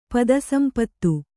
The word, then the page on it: ಪದಸಂಪತ್ತು: pada sampattu